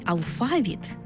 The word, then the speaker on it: алфавіт: Наголос у слові «алфавіт» на другому складі основний у нашій мові, бо збігається з грецьким «алфавітон», від якого й походить українська лексема.